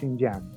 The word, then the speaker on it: Xinjiang: Xinjiang, se pronuncia Xinjiang.